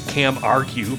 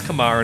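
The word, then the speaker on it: Camargue: Initially, I thought it was Cam Argue because that's how it's spelled, but it's not. It's Kamar, and it's the region of France.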